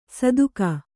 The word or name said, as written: ಸದುಕ